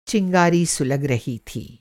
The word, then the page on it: सुलगना: سلگنا